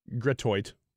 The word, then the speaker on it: Gratiot: How do you say that street? Gratiot.